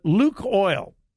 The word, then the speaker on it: Lukoil: But Luke Oil, I never was for sure how to pronounce it, L-U-K-O-I-L.